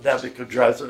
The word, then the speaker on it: Nebuchadnezzar: And the new people, the new rulers that are in the line of Nebuchadnezzar, that's really how you say his name, they didn't have that much to do with Daniel.